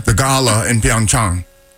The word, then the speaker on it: Pyeongchang: The correct pronunciation is Pyeongchang. Chang. Like when you go see your doctor. – Pyeongchang. – A little bit of a drawn out A.